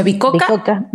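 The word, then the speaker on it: Bicocca: a ¿Cómo se pronuncia? – Bicoca. – Bicoca, Italia, eh, Alliance University en India y Centennial College, Canadá. ¿Está bien? Sí, lo dije bien.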